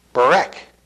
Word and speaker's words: berek: The related Hebrew noun for knee is berek. Berek.